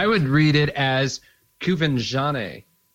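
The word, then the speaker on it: Quvenzhané: According to IMDb, her name is pronounced Quvenzhane. Jane Kova. Yeah, I guess. Whatever. – It looks like the accent is on the Ven, so it would be Kovenjane.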